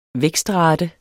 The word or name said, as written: vækstrate